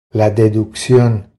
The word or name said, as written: La deduccion